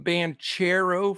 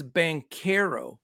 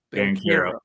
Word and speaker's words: Banchero: And I called him Banchero for a while, but it is Banchero. – Banchero.